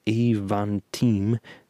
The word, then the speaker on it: Avantime: This one box weirdo called the Aventime, pronounced Avantime. Oh, I've been pronouncing it wrong the whole time.